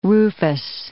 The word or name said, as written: rufous